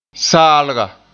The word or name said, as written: saalga